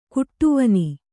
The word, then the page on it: ಕಡುವೇನೆ: Kannada